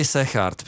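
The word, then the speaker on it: Segaert: kterou vyhrál Alex Sergent, nebo jak se vyslovuje? – Říkali Sechard, dneska jsem jako, to, nikdy jako jsem to vlámsky, neříkal, jo. Ale prej to G sečte jako H, tak já jim budu věřit a PCS to tak taky má pod tím, pod tím amplionkem, tak Sechard.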